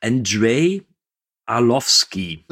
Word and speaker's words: Andrei Arlovsky: Und als Gegenspieler haben wir ja den, jetzt muss ich aufpassen, dass ich nicht falsch ausspreche, Andrei Arlovsky.